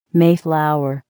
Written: Mayflower

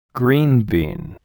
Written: green bean

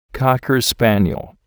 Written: cocker spaniel

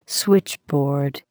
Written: switchboard

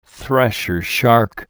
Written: thresher shark

